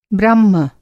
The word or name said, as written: ब्रह्मा